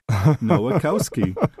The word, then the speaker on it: Nowakowski: My younger brother, Bob Robert, made up a card when people would have difficulties, and he'd say, "Have you ever seen a cow ski?" Nowakowski.